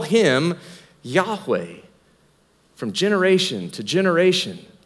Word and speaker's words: Yahweh: That when God reveals his name, YHWH, that it would have been pronounced in that day, Yahweh.